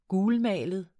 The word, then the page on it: gulmalet: [ ˈguːlˌmæˀləð ]; [ -ˌmæːləð ]